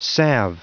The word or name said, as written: salve